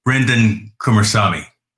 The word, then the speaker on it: Kumarasamy: Ladies and gentlemen, Brenden Kumarasamy.